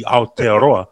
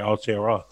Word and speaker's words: Aotearoa: But then there's the likes of Justin Marshall and your Grant Nisbet, who would just refuse to say it correctly. They'll just say Aotearoa. – Yeah. – Aotearoa.